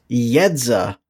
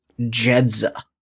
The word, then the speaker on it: Yedza: So, as we've been saying, it's pronounced Yedza, not Jedza, because it's Eastern European, and you can tell by all the Russian she uses in her story.